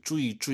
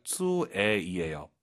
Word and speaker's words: 最: UI 사이에 에 넣으셔야죠. 쭈이, 쭈이가 아니라 쭈에이에요. 빠르게 발음하니까 잘안 들릴 뿐이죠.